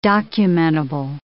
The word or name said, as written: documentable